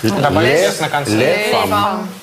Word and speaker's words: femmes: «Les femmes». «Les femmes», да. – «Женщина». – А. Будет добавлено «s», но на произношение это никак не повлияет.